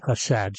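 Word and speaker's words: chesed (חסד): That day, she introduced me to the Hebrew word chesed, H-E-S-E-D, chesed. Sometimes it's spelled chesed with a C in front of it.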